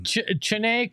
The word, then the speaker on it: Chennai: Chennai. – Are you sure that's how it's pronounced? – That's how it's pronounced.